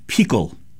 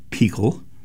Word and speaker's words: Pichl: who is the Czech composer Václav Pichl. His surname is spelled P-I-C-H-L in English.